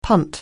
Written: punt

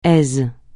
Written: aise

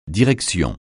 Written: direction

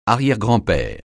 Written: arrière-grand-père

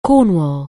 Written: Cornwall

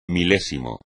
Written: milésimo

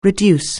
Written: reduce

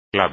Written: clave

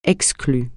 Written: exclu

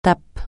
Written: tape

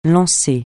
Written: lance

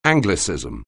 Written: anglicism

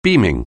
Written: beaming

